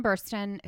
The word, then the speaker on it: Burstyn: So Sarah is played by Ellen Burstyn. – Yep. – Did I say that right? Yeah. Yep. Ellen Burstyn, who I love.